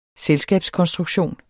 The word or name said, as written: selskabskonstruktion